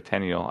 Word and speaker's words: Tenniel: A lot of Carolian scholars attribute this illustration, Tenniel's, is it Tenniel or Tenniel? I've been saying Tenniel, like bicentennial.